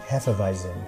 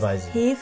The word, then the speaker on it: Hefeweizen: So is that how you say that? I'm saying it wrong then. – How do you say it? – Hefeweizen. – Oh, it's not too bad. – It's Hefeweizen.